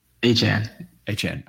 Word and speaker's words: Achane: They have a significantly negative EPA per play on runs, which is surprising given Achane, and I want to pronounce his name, but it's Achane, right? – Achane. – Okay.